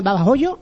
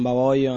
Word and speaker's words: Babahoyo: Lino es ecuatoriano, nació en Babahoyo, ¿se llama? ¿Lo he dicho bien? – Sí, claro, Ecuador y en Babahoyo, donde mi tierra natal, bien dicho.